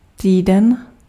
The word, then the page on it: semaine: France; [sə.mɛn]